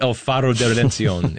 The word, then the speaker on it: El Faro de Redención: But tell us what El Faro de Redención is all about. – Well, first, you pronounce it very well.